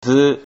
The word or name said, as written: zu